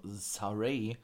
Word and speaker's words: Sarray: und das kam nur gleich danach erstmal Olle Sa Saray, so nennen sie die ja eigentlich ja Saray, aber bei NXT wird sie nicht mit AI, sondern mit AY geschrieben, also Saray und doch so ausgesprochen.